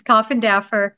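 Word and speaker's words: Coffindaffer: Okay, so I have to tell you, that's actually how it's pronounced, is Coffendoffer. But so many people say Doffer that it's just kind of stuck.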